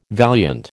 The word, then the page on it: valiant: American English